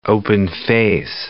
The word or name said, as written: open-face